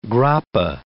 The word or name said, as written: grappa